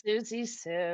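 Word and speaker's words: Siouxsie Sioux: And I think it's pronounced Susie Sue. I feel like someone's going to slap me for mispronouncing it. I think it's right. – It's Susie Sue.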